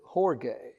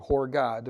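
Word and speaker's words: horge (ὀργή): It's O-R-G-E-I. That's how we transliterate it. But it has the H mark over the O, so we pronounce it horge, is how we pronounce it. Or horgai, depending on how you pronounce that diphthong there.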